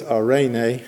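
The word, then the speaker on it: arene (εἰρήνη): The Greek word, E-I, is pronounced ah. And then R, the E is pronounced like an A in both, so it's arene in Greek.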